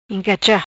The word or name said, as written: Incacha